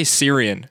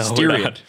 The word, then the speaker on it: Styrian: I keep trying to say Syrian. And like, we're not racing in Syria. – No, Styrian.